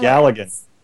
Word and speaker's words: Galligan: Galligan.